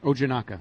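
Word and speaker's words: Ojinnaka: Now, listen, I might be swayed by you a little bit if, say, next week, I can't pronounce the guy's name, Quinn? – Ojanaka. – Ojanaka is suddenly supplanting Dan Conley.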